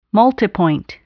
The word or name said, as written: multipoint